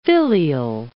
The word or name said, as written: filial